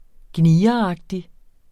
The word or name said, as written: gnieragtig